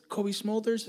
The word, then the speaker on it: Cobie Smulders: So, one of my favorite stories you told me is Kobe Smulders.